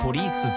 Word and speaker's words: polis: 警察はポリスって伸ばすけど、テクノポリスの方は普通にポリス。